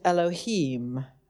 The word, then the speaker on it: Elohim: It's written out Elohim. I'm not Jewish, but we pronounce it Elohim, okay?